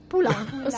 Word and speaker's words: Poulenc: Ho sbagliato la pronuncia, no! Dai! – Poulenc. – Scusa, dai. – Anche se di solito la consonante vicina finale dei cognomi francesi si pronuncia sempre.